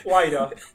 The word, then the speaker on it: Huayra: Właśnie pisze się Huayra, a czyta się Huayra.